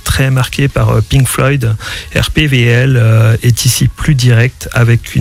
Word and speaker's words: RPWL: Alors, je viens de prendre RPWL, ce qui se prononce RPVL.